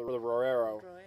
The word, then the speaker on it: Roero: I want you to keep that number in mind when reads the tech sheet for the Roero. How do you say? – Oh, I'm being silly. Roero.